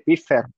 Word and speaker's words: Pfizer: Ya ha salido, no, te iba a decir que de la de Pfizer, ¿cómo se dice? Pfizer,